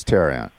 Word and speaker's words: Tehran: It's Tehran. Tehran. As President Carter said to me, Tehran. That's how he pronounced it.